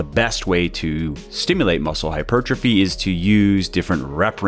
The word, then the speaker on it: hypertrophy: And in this episode of the podcast, we're going to talk about muscle hypertrophy, which can be a rather confusing subject. I mean, it's a confusing word. Many people don't even know how to say it.